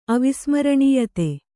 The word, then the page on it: ಅವಿಸ್ಮರಣೀಯತೆ: kannada